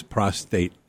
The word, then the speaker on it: prostate: And it is prostate, John, just so you don't mispronounce that again.